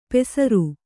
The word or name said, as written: ಪೇಸರು